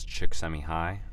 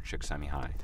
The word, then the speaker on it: Csikszentmihalyi: But I'm pretty sure the pronunciation, and forgive me if I'm wrong, you listeners can correct me, but I believe it's, Dr. Csikszentmihalyi. – And some people call him Chick, which is C-S-I-K. That's how you spell it.